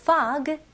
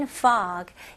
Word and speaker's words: fog: fog 그리고 mist. 네, 첫 번째 단어는 fog. 이거는 발음을 F 발음으로 하셔야죠. 그래서 fog라고 하시면 안 되고 입술이 붙으면 안 돼요.